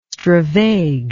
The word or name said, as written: stravage